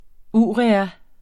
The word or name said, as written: urea